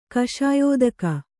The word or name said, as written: ಕಷಾಯೋದಕ